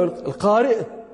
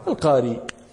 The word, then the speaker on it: القارئ: القاري أو القارئ من أيش؟ من القراءة. وقد تسهلون فتحذفون الهمزة وتقولون القاري، وهي لهجة من لهجة العرب.